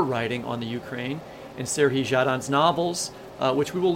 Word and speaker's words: Serhiy: We're going to end with a poem from Serhii, is that the right way to say it? – Serhii.